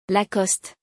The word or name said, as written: Lacoste